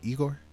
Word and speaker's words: Igor: We got Tyler Creator with his album Igor. I said that right, Igor? – Yeah. yeah. yeah.